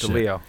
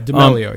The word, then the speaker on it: D'Amelio: I just didn't know how to say D'Amelio? The last name. I don't know how to say that D'Amelio. – Shit. – D'Amelio. – Okay, D'Amelio, yeah.